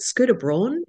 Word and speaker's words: Scooter Braun: that's just been in the news this week is it Scooter Braun? Is that how you say his name? All of Yes.